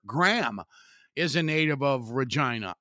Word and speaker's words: Regina: and he was a star player for the Regina Rats. And even though when you look at the word Regina, it's easy to want to say Regina. It's spelled, in case you don't know, R-E-G-I-N-A.